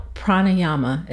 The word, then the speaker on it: pranayama: pranayama.